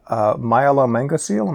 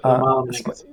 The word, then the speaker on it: myelomeningocele: And the first was that you became a specialist in surgery on a fetus to repair myelomeningocele. Am I pronouncing that right? – Yep. Spina bifida myelomeningocele.